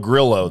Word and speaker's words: Grillo: Emiliano Grillo at 25-1. – You don't say Grillo. Grio. – It's Grillo. – L's are silent in Argentina. – It's Emiliano Grillo.